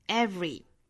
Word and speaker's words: every: 那这个词其实是有两种读法的，我们可以把它读成 every，也就是 v 和后面的 re 之间不加元音的，直接就是 v 上齿去摩擦下唇之后就去发这个 re 的发音 v。